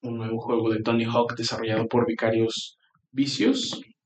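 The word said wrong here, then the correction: Vicarious Visions: lo dije bien? Vision. – Vision. Eh, sí, Vicarios Vision.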